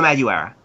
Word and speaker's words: Madureira: Zeb Wells and Joey Madonna. I can't pronounce his surname. – It's Joe Madureira.